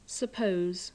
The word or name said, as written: suppose